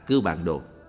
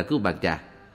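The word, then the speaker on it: Cưu Bàn Đồ: Đúng ra những chữ này phải đọc là Cư Bàn Đồ, nhưng hiện nay những người tụng chú đều đọc là Cư Bàn Trà.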